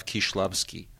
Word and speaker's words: Kieślowski: Like we say Krzysztof Kieślowski. It should be pronounced Krzysztof Kieślowski.